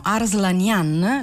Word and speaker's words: Arslanian: Arslanian, l'accento fonico va in fondo,